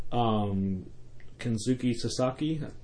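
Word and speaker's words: Kensuke Sasaki: Kenzuki Sasaki, I butchered that name. – No, that's right, yeah.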